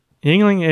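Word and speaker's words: Yuengling: Yingling is the oldest brewery in America. – That's right. We knew that. Comes, said that right.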